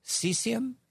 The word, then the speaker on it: cecum: They have something called a cecum. How do you say that word? – Yeah. Cecum or cecum. Yep, that's correct.